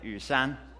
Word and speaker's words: Yushan: Yushan.